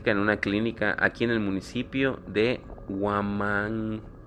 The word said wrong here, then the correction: Huimanguilo: Perdón, pero es que no sé pronunciar bien. se escribe, es Huimanguilo. – Huimanguilo. – Ándale.